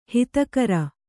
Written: ಹದಗಾಱ

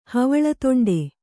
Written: ಹವಳದೊಂಡೆ

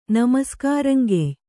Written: ನಮಸ್ಕಾರಂಗೆಯ್

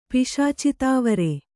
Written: ಪಿಶಾಚಿತಾವರೆ

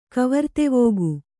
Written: ಕವರ್ತೆವೋಗು

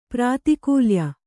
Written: ಪ್ರಾತಿಕೂಲ್ಯ

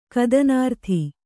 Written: ಕದನಾರ್ಥಿ

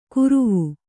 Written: ಕುರುವು

